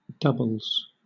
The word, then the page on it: doubles: British English